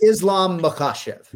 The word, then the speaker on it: Islam Makhachev: It's Islam Makhachev.